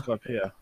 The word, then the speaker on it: Skypiea: I think that's it, Skypiea? – Skypiea.